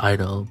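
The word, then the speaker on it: (G)I-DLE: I know it's pronounced Idol, but, you know, I think everybody knows it as G-Idle, so I'm probably just going to say it like that. But, yeah, G-Idle and Myung's look absolutely stunning in her pictorial for Elle magazine.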